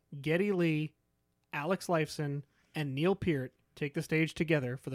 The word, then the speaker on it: Peart: Just Neil Peart.